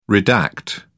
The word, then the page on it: redact: British English